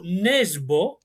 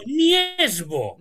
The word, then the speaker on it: Nesbø: És que depèn d'on vagis, es pronuncia Jo Nesbø o Jo Niesbø.